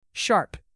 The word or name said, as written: sharp